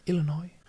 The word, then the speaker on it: Illinois: Mm i Eureka, Illinois. Hur Uttalar man det så? Illinois? – Illinois.